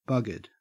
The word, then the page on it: buggered: Australian English